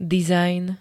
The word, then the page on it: dizajn: [-d-]